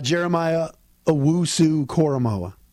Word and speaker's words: Jeremiah Owusu-Koramoah: Jeremiah Owusu-Koramoah.